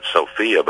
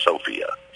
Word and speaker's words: Sofia: I used to call it Sofia, but I understand it's pronounced Sofia, so I stand corrected.